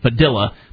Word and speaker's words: Padilla: In fact, one of the most high-profile cases at this point in the United States has been Jose Padilla, or as his family allegedly claims is actually pronounced Padilla, but I just can't bring myself to do that.